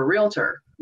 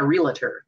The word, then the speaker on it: realtor: And when you sell homes, you're a realtor, not a realtor. There's not three syllables in it.